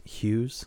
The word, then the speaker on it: Hughes: So it's not, you don't pronounce the G, it's just Hughes.